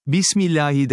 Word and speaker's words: Bismillahi: Bismillahi derken, H, harfini belli etmek lazımdır.